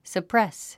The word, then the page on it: suppress: səprés; サプレス